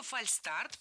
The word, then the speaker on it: фальстарт: Фальшстарт пишем и произносим без «ш». Почему так? Слово «фальстарт» пришло к нам из английского языка «fail start», то есть ошибочный старт. Никакого звука «ш» здесь нет.